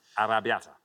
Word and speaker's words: arrabbiata: In an arrabbiata, you might have some. – Oh, you might do. – You might have some arrabbiata, yeah.